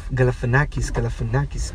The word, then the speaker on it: Galifianakis: Buena actuación de parte de su elenco, especialmente Zach Galifianakis. – Uh-huh. – Sé que no es el apellido así como se pronuncia. – Galifianakis.